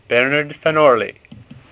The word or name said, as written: Orley